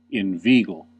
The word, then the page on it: inveigle: British English; American English